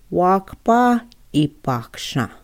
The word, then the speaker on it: Wakpa Ipaksha: How we pronounce the Flandreau relatives is the Wakpa Ipa. Ipaksha. Wakpa Ipaksha. Okay, so if we can pronounce that together. Wakpa Ipaksha.